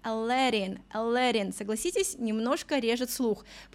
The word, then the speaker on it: Aladdin: Для нас это был Aladdin, а оказывается, в оригинале этого юношу зовут Aladdin, Aladdin. Привыкайте, именно так, ударение на второй слог.